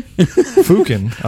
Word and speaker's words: Fuckin: It's spelled F-U-C-K-I-N. All right. That's not how they... – Fucken? – Fucken, I think is what it actually is.